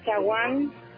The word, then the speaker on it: Sawan: Dice Ajaiz Pinji Ji y Sawan Pinji Sirpal Iskin.